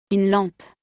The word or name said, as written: Une lampe